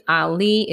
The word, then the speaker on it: Ali: If you have Facebook, my name is London Ali. It's spelled L-O-N-D-O-N. Last name is A-L-I.